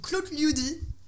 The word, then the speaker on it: Klotljudi: Alors il y a le groupe Claude Liudi. Ça s'écrit K-L-O-T-L-J-U-D-I.